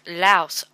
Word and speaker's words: Laos: So it rhymes with blouse. Louse. Oh, it's louse. And lao rhymes with cat. Oh. All other pronunciations, including Laos, are incorrect. Okay, that's how I was pronouncing it. Laos. It's actually louse or lao.